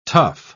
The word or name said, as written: tough